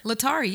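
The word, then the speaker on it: Laetare: Might not give up anything for 40 days, but they're going to be wearing rose on I don't know how to pronounce it, Latier Sunday. How do you say that? – Latari? – Latari, yeah, that one. Like Atari. – Yes. – But with an L.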